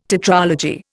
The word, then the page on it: tetralogy: Indian